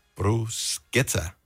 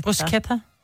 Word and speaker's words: bruschetta: Hvordan udtales det så? – Bruschetta. – Med K? – Ja, CH giver K på italiensk. – Gør det, når du skætter? Ja. – Bruschetta.